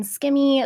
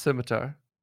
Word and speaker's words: scimitar: He gave her a rune skimmy at one point. Skimmy. – Don't say it properly. It's scimitar.